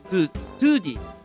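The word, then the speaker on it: 唐手: これがまあトゥーディー、空手、あの辛い手と書いてトゥーディー。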